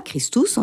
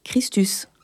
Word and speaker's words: Christus: Par exemple, les U sont prononcés U et pas Ou. Donc, on ne dira pas Christus, on dira Christus, par exemple.